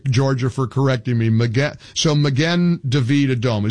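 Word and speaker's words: Magen David Adom: It's Magin David Adom. What's the proper pronunciation, Uri? – So you got it right on the second time. It's Magin David Adom.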